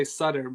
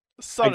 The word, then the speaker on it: Sutter: Yeah, I mean I say Sutter, but Sutter. I guess it's an Americanized version of Suter, so or something.